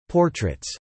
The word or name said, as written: portraits